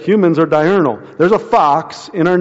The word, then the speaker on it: diurnal: Nocturnal and diurnal. I think I'm saying that right. Am I saying that right? Yes.